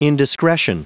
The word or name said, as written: indiscretion